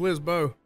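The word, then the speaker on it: Liz Beau: playing the role of Liz Bow, or is it Liz Boy? Liz Ba. How do you say that? I think it's Liz Bow. – B-E-A-U? – Yeah.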